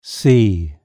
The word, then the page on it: sea: British English